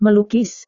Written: melukis

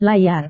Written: layar

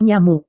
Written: nyamuk

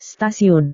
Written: stasiun

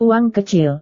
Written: uang kecil

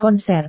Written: konser

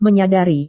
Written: menyadari

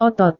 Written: otot

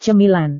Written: cemilan

